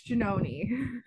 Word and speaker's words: Giannone: It's Giannone.